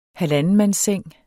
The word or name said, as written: halvandenmandsseng